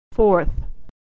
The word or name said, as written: fourth